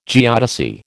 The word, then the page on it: geodesy: American English